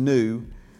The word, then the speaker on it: pneu: So from Rick Renner, the root P-N-E-U, pronounced new, communicates a dynamic movement of air.